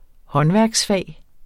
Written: håndværksfag

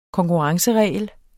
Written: konkurrenceregel

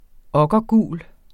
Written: okkergul

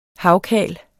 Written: havkal

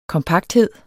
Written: kompakthed

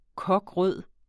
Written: kokrød

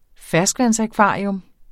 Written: ferskvandsakvarium

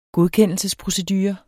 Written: godkendelsesprocedure

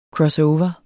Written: crossover

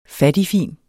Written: FATTIGFINT

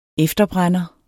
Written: efterbrænder

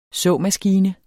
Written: såmaskine

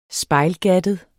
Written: spejlgattet

